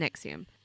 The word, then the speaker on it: NXIVM: And if you want to look this up, it's spelt in a really weird way. It's NXIVM, but it's pronounced Nexium.